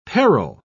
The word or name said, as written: parrel